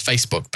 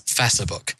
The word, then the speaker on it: Facebook: ACE as ace in that context. It pronounces it more like the ACE in macerate. So it's Facebook.